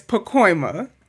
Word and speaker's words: Pacoima: So thank you for the correction. Pacoima is Pacoima, right? I'm saying it right now. I got it.